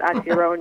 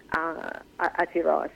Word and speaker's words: Aotearoa: I think you need a New Zealand accent for this, but Aotearoa, New Zealand. – Yes. – So, for example, our closing night film, Kainga, is from Aotearoa.